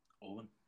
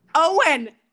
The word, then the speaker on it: Eoin: How do you spell or how do you say this name? – Which one? – E-O-I-N. – Owen. – Owen!